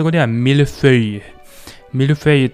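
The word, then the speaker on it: feuille: フランス語の葉っぱはフェイユって発音します。フェイユ。 F、 EU に I、 LLE。 F、 EU に I、 LLE でフェイユって発音します。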